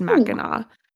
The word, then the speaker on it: Mackinac: and it's pronounced Mackinac, which it does not look like Mackinac.